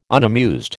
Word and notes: unamused: American English

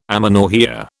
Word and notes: amenorrhoea: British English

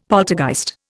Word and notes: poltergeist: Indian

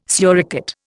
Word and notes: suricate: Indian

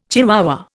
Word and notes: chihuahua: Indian

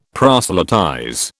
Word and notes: proselytize: British English

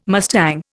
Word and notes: mustang: Indian

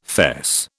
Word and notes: fess: British English

